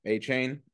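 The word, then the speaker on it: Achane: I mean, and then I'm gonna butcher his name. Arcane. Is that it? – A-chain. – I knew I'd butcher it. A-chain.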